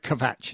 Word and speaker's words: Kvatch: And you pronounced the K, right? Kvatch, is that right? – It's supposed to be the phonetic spelling of the German for rubbish.